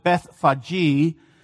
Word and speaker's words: Bethphage: But just keep in mind, the name Bethphage, or as some people like to pronounce it, Bethphage, means House of Early Figs.